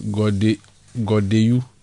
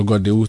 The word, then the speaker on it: Godenu: God's Sweet Choices from Hohoe says the town in relation to the Hohoe story is pronounced Godewu, though the spelling is Godenu. Okay, so Godewu.